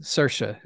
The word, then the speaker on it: Saoirse: Oh, but, that's how you pronounce that name? – Yeah, Saoirse is the name.